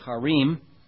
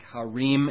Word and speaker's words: Harim: By the way, you notice on some of those words that end in I-M, like Harim, H-A-R-I-M, I don't say those as Harim because of the way the pronunciation is in the Hebrew. It's more of the im sound.